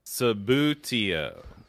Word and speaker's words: Subbuteo: Hey, Subbuteo. – Subbuteo. – So just think about booty.